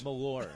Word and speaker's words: Malort: Malort. – Oh. – Malort. – Malort. – Rhymes with Voldemort. – Malort.